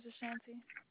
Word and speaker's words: Ashanti: My name's Ashanti. – Ooh, spell that for me, please. – A-S-H-A-N-T-I. – A-S-H-A-N-P-I? – T-I.